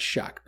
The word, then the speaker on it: Shough: It's spelt S-H-O-U-G-H, and it's pronounced Shuck, because I kept hearing it pronounced and going, that can't be right. No, Tyler Shuck says the correct pronunciation of his last name is Shuck.